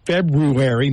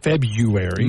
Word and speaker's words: February: It's February, not February. – Mm. – Yeah, that R is in there, but we like to leave it out.